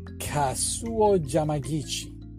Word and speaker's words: Kazuo Yamaguchi: This ramen chef or ramen maker named Kazuo Yamaguchi. Did I say that right? Your Japanese is better than mine. Yes.